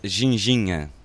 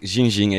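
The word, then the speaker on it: ginjinha: This drink is called ginginha. – Ginginha? – The drink ginginha, exactly like you pronounced it.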